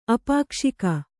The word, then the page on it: ಅಪಾಕ್ಷಿಕ: apakṣika